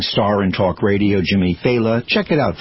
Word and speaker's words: Failla: His name is pronounced Faila. Faila. It's spelled F-A-I-L-L-A. So people go Faya, Faila. You know, but it's Faila.